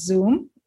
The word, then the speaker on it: Zum: As you rightly pointed out, the name of the company is Zoom. It's spelled Z-U with a line on the top and M.